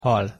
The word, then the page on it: hal: Hungarian